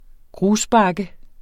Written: grusbakke

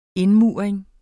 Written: indmuring

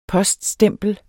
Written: poststempel

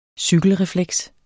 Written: cykelrefleks

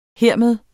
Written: hermed

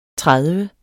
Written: tredive

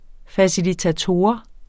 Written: facilitator

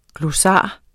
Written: GLOSSARIET

